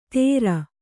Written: ಥೇರ

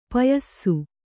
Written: ಪಯಸ್ಸು

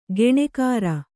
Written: ಗುಣಕಾರ

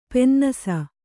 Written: ಪನ್ನಸು

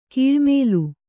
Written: ಕಳ್ಳಮಾಲು

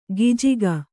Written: ಗಿಜಿಗ

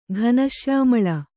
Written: ಘನಶ್ಯಾಮಳ

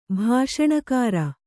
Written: ಭಾಷಣಕಾರ

